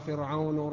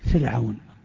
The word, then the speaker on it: فرعون: أنا أقول للجميع هذا الكلام، كلمتان يكثر الخطأ فيهما، فرعون ومريم، يخطئ كثير من الناس فيفخم راء فرعون ويرقق راء مريم، يا مريم، ويرقق راء مريم فيقول يا مريم، يا مريم اقنتي لربك. والصواب العكس فيهما، الصواب العكس فيهما.